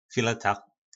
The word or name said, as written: fileta